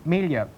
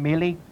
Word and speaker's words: Mealy: In Temple Cross, Richard's family name is pronounced Melia, other local pronunciations being Melee and Mallee.